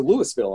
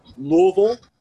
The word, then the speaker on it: Louisville: Yeah, you gotta pronounce it correctly. You know, you can pronounce it Louisville, you can pronounce it Louisville, but there is no Louisville.